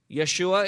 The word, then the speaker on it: Yeshua: Yeshua is the Hebrew way of pronouncing it, yes?